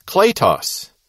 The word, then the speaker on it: kletos: Now, when this meaning of kaleo is recognized, it makes possible an understanding of the adjective kletos, K-L-E-T-O-S, and that E has the line over it, it's the long E, and the Greek participle klesis, K-L-E-S-I-S, again a long E there.